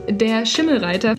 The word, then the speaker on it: Der Schimmelreiter: The first one we read, I distinctly remember that one, and German students might know it as well, was called Der Schimmelreiter. I'm specifically using the German pronunciation because it would feel weird to do it in English.